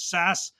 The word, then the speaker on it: SAS: called SAS, so they pronounce themselves as SAS, which is a I guess I would describe them as a uh, provider of data services, uh, software that analyzes data.